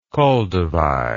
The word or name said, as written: koldewey